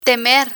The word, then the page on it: temer: spanish